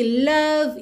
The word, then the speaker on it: love: Atenção aqui, love you. Lembra que o é final de love não é pronunciado, então você já junta o V no you.